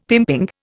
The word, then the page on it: pimping: Indian